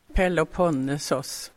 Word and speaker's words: Peloponnesos: Alltså det där är en liten bit av fastlandet och Peloponnesos. – Jaha, nu hör det grekiska uttalet, va? Peloponnesos.